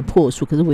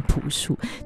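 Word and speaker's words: 朴树: 这一段的学员安排的这歌曲呢都跟花有关，开场呢王大文的那些这些花儿送给你，而这首歌曲则是呢这位中国的男歌手，其实他名字赵真的赵发音是念破书，可是我以前都一直念朴书，就觉得念破书这个名这个音这念起来我自己觉得就是好像哪里不顺的感觉。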